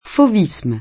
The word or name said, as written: fauvisme